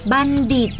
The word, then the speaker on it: บัณฑิต: บัณฑิตไม่อ่านว่าบัณฑิตล่ะทอมนโทออกเสียงด้วยสองเสียงออกเสียงเป็นทอทหารก็มีเช่นมนทามนโทมนทนและออกเสียงเป็นดอเด็กก็มีเช่นบัณฑิตมนด